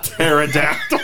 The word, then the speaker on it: pterodactyl: Taken by the pterodactyl. The P is silent.